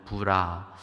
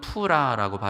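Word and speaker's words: Purah: 그 부하의 이름이 공교롭게도 부라, 히브리어로는 푸라라고 발음이 되는데 아마 방패지기 정도로 보면 좋을 것 같습니다.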